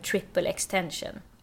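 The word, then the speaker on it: XXXTentacion: Som heter, jag tror man uttalar det, Triple Extension. – Ja, exakt.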